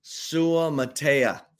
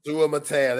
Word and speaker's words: Suamataya: I can't pronounce Kingsley Salmataya. I botched the hell out of that. My bad, bro. – Kingsley Suamataya. – Suamataya. There we go. That sounds better.